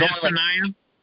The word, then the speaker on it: Zephaniah: Forgive me for mispronouncing it. Zephaniah. – Zechariah? – And it was, it's a small book. I mean, there's Zephaniah? – That sounds right.